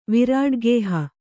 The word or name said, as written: ವಿರಾಡ್ಗೇಹ